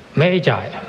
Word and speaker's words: Magi: 好，有一本书是英文的名字叫 The Gift of the Magi，这个 M-A-G-I 啊，我查了一下这个发音叫 Magi 是什么意思呢，就是圣经中的东方博士，这个意思王博士肯定知道啊。